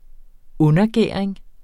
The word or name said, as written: undergæring